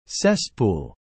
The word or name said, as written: cesspool